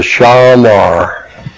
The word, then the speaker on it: Shamar: Shamar is S-H-A-M-A-R, pronounced Shamar, actually, in the Hebrew.